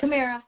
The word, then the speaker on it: Camara: Um, later on today, Jessica the Cobra Camera, who Camara, I don't know how to pronounce it, you might be able to help me with that. – Camara.